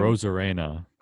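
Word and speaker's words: Arozarena: The rookie Randy Arozina. How do you say his name? – Rosarena. – Rosarena.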